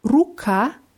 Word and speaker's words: рука: Ruka heißt Hand oder Arm. – Ruka. – Ruka. Die Betonung ist auch auf A.